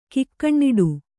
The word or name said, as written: ಕಾಕನಾಡು